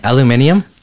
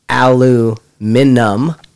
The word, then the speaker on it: aluminium: Or aluminum, because there's no extra I in it either. So it's still, everyone has a different idea of how to pronounce that. But most people outside of the United States I've met say aluminium. – Yeah. – But there's no eum at the end either. – Yeah, there is. I-U-M is the ending of the word.